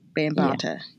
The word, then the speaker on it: Bambaataa: Bambaataa.